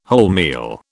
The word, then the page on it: wholemeal: British English